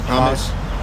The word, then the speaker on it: Hamas: Ham, how you pronounce Hamas? Hamas. – That sounds suspiciously close to hummus.